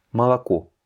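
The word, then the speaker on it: молоко: On ne va pas dire Moloko mais Malako.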